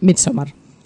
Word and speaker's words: Midsommar: Bueno, pues creo que ha quedado claro ahí esa aseveración tuya sobre Midsommar. No sé si se pronuncia así o no. Pero bueno. – Sí, dicen ahí, Midsommar.